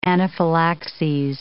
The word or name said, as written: anaphylaxes